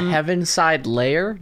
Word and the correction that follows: Heaviside Layer: It's Heaviside Layer is how it's pronounced. It's spelled Heaviside space Layer.